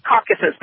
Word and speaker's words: Caucasus: is that Caucasus or how do you pronounce that? – Caucasus. The Caucasus. – Okay. – Yeah. – The Caucasus Mountains.